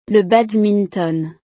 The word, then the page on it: le badminton: frances